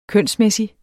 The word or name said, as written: kønsmæssig